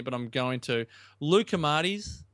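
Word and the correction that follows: loukoumades: Is that how you pronounce it? – Lucamades. – Lucamades.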